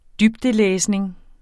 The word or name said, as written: dybdelæsning